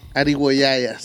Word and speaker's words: Arigüeyaya: Y tengo entendido que, no tengo entendido, hay un éxito total que se llama Arribo Yaya. No sé si lo dije bien. – Arigüeyaya, sí, es...